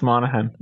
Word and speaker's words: Monaghan: Monaghan. Is that right? Am I saying that right again? – It's the silent G. It's getting you. It's Monaghan.